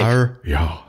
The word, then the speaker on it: Arya: Schwester 2. Arya. Arya. Ich, ich, ich, guck, ich, ich spicke gerade auf den Pronunciation Ich hab's, ich hab's auch offen, ja. Okay. Arya. Zweite Schwester.